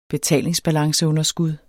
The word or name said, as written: BETALINGSBALANCEUNDERSKUD